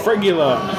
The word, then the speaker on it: fregula: F-R-E-G-U-L-A. – Fregula.